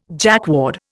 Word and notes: Jacquard: Indian

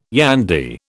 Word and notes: yandy: British English

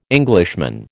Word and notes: Englishman: American English

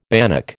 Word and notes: bannock: American English

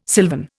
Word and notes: sylvan: Indian

